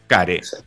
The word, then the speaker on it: Care: Entendiendo que Care, chiquillos, chiquillas, chiquillas, se escribe e. C-A-R-E. Care. Se dice Care porque está en inglés.